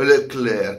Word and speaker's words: Leclerc: o Leclerc, eh, Leclerc, come Chantecler. Cioè, ma io devo pronunciare, ma cosa me...